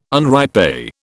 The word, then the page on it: unripe: British English